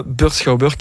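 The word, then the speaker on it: Beursschouwburg: Schauburg, S-C-H-O-U-W, et Burg, B-U-R-G. Donc B-E-U-R-S, S-C-O-U-W, ah, mais moi je bug, B-U-R-G.be, Burskauburg, qui veut dire le théâtre de la bourse. Sur burskauburg.be,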